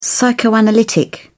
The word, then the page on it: psychoanalytic: British English